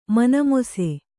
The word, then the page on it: ಮನಮೊಸೆ: kannada